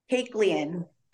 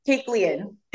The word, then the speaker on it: Kaiklian: Kaiklian.